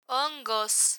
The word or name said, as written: hongos